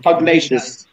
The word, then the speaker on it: Pugnacious: PUG Gnacious, I think I've pronounced. Thank God I'm not. – Pugnacious. – Pugnacious, that's it.